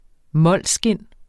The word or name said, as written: molskind